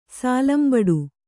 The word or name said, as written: ಸೋಲಂಬಡೆ